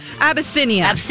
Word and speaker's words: Abyssinia: It's the production of Circus Abyssinia. I hope I'm saying that right. – I think so. – Let's see yes. – Here. – Abyssinia. – Yep.